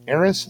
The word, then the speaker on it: Ares: Ares is how you say it.